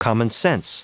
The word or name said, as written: commonsense